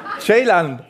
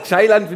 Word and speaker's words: Ceylan: Ceylan, jetzt ausprobieren wir mal. Ceylan finde ich geil. Chai trinkt man auch.